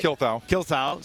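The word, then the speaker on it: Kuelthau: For those of you who don't know, is an attorney with Davis and Kilthow. Did I say that right? – Kilthow.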